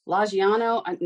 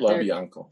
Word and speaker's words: LaBianco: And that's the same as where the other family that died then to the LaBianco. No, I'm not saying that right. But LaBianco.